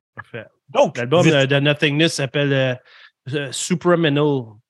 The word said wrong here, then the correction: Supraliminal: Supraliminal. Su Supraliminal. Mm-hmm. J'étais tellement proche, il manquait une syllabe.